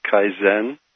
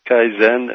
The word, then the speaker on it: Kaizen: First of all, it's pronounced Kaizen. – Ah, Kaizen. – Kaizen, and it's a Japanese word that means continuous improvement.